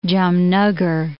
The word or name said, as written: Jamnagar